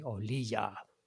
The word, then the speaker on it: Olija: It's called Olia. Which I think is the right way to pronounce it, because it's spelled O-L-I-G-A-A. So it could be Olija, but in the game I hear someone say Olija. So that's what I'm going with.